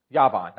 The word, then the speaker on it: Yavan: Javan. And by the way, how would you say that if you were a Jewish person? Yavan. That's right. You say Yavan.